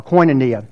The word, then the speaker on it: koinonia: Like 21 times we see this word, and the word is koinonia, or koinonia, however you want to pronounce it.